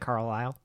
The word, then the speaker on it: Carlile: How do you say that? – It looks like Carlile.